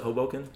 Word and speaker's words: Hoboken: So I'm live, just so everyone knows, in their apartment in Hoboken. Do you pronounce it Hoboken? – Yeah. – Hoboken. Or Hoboken. Yeah. Okay. – Both work.